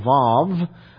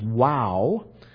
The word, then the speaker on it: Vav: Sometimes it's pronounced as Vav. I prefer the pronunciation as Wow.